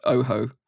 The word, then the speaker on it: Ojo: It, it's spelled O J O, but it's pronounced Oho.